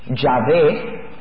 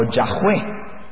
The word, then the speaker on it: Yahweh: Los eruditos nos dicen que lo más cerca a pronunciarse debe ser Yahvé o Yahweh. Yahvé o Yahweh, pero no saben realmente cómo se pronunciaba, porque no tiene vocales.